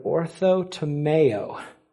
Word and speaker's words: orthotomeo: It's the word orthotomeo. It sounds like tomato, but it's O-R-T-H-O-T-O-M-E-O. Let me spell that one more time. O-R-T-H-O, ortho. It's pretty easy. And then tomeo, T-O-M-E-O.